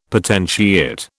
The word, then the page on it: potentiate: British English